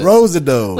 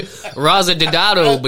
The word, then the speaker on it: Rosado: Rosado. – Listen to this. Before this podcast started, he said, hey, how do you think you say this name? And it's spelled R-O-S-A-D-O. And I said, it's probably Rosado.